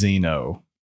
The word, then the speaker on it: Xeno: Well, it's spelled with an X and it's Xeno.